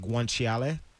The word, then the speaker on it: guanciale: It's pronounced like guanciale.